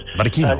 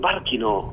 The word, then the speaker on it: Barcino: igual que Tarragona, és a dir, Barcelona, hi ha dubtes sobre la pronúncia en llatí del nom, eh? – Ah. – Sí, perquè tendríem a dir Barcino, però també hi ha els llatinistes... – Barquino. – Diuen que es diu Barquino.